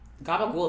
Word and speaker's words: capicola: Capicola. – That's how you spell gabagool. Gabagool is really Capicola, but it's the way people pronounce it.